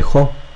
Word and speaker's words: Jo: Cuéntanos y por supuesto eh, los links están en la página oficial del Grupo Cultura Adictiva y en sus redes de Jo. Háblanos de esto, amigo. Bueno, me podéis encontrar como JO con el acento en la O,